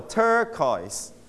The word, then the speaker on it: turquoise: stress on the first syllable turquoise Turquoise.